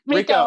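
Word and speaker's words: Ricou: So just right before Halloween, it features Raku, was that how you say it? Raku Browning? – Rico.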